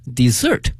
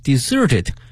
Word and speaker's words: desert: 沙漠这个词我们已经讲过很多遍了哈，那么这个词的发音哈 desert 和呢另外一个词呢 desert 有的时候会弄混。 The village was deserted after the flood，在洪水过后呢这个村子被呢遗弃掉了。但是呢这两个词的它的拼写呢一模一样的，但是呢发音不一样，名词 desert 沙漠，动词呢 desert 抛弃。那么还有一个词呢 d-e-s-s-e-r-t 多了一个 s，它的发音是 desert，它是指呢我们去点快餐的时候呢会有一些小的甜品，比如说像奶昔啊冰激凌啊等等，那么它通称为 dessert 啊，甜品。